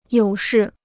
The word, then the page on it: 有事: yǒu shì